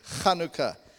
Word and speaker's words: Chanukah: You know, so whenever you see the H, it's a Ch in actual fact. And you see it spelled in so many different ways in phonetically in English. But you're really meant to say Chanukah.